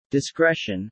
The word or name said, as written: discretion